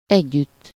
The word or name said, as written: with